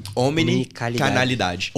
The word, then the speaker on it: Omnicanalidade: Omicronidade. Eu falei certo? – Omnicanalidade. Omnicanalidade.